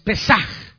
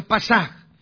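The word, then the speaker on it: Pesaj (פסח): La palabra hebrea para Pascua es Pesaj. Algunos la pronuncian Pasaj.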